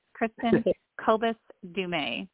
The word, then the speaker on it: Kristin Kobes Du Mez: And yes, I'll handle the name pronunciation. It's an impossible name. It's Kristin Kobes Du Mez.